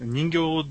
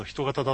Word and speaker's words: 人型: 人形じゃないぞ人型だぞというツッコミが入っておりますね。